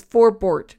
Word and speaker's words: Forbort: His name is Forbort, not Forbert. I keep calling him Forbert.